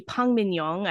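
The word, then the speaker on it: Park Min-young (박민영): The drama stars Park Min-young, or actually Park Min-young. I found out that's actually how you pronounce her name, and I was in shock when I found out.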